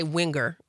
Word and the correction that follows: Wingra: Winger. Say it again. – Yep, that's all right. – Say it again. – You got it. Wingra. – Winger. – Wing and ra.